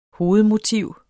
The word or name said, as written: hovedmotiv